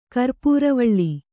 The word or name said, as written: ಕರ್ಪುರವಾಳೆ